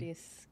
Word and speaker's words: Gregoris: É Gregores? Gregores, como que pronuncia Gregores, seu sobrenome? Gregores.